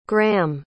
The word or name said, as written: graeham